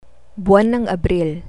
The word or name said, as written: buwan ng abril